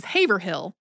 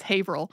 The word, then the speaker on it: Haverhill: But they said the name of a Massachusetts town as it is spelled, which is Haverhill. But the way that Massachusetts town is pronounced is Haverhill.